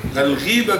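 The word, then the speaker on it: الغيبة: ولكن النطق الصحيح الغيبه بكسر الغين.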